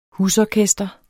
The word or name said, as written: husorkester